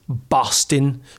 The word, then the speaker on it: bostin: So bostin wouldn't be how I would pronounce it now. Is Boston. So almost a U sound.